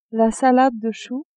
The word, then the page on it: salade de chou: female